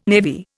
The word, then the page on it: navy: Indian